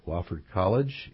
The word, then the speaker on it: Wofford College: So let me just tell everyone that Dr. Terrell is a professor of economics at Wofford College in South Carolina.